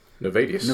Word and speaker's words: Nayvadius: Nayvadius.